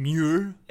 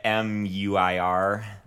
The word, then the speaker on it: Muir: John Muir. I think that's how you pronounce his last M- name. Muir. – Uh, m U I R. – Muir. – Muir. Uh, no, Muir. Uh, Muir. Let's say Muir.